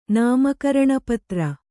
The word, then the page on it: ನಾಮಕರಣಪತ್ರ: nāma karaṇa patra